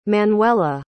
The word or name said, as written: Manuela